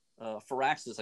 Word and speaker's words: Firaxis: Firaxis, I guess, is what they're actually called, if I'm going to say it right.